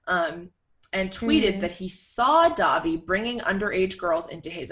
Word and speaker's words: Dahvie: I think it's Davi. It's spelled D-A-H-V-I-E.